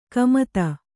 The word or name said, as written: ಕಮತ